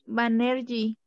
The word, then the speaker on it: Banerjee: ¿Es el de Banerjee o Banerjee o cómo se pronuncia? – Sí, Banerjee.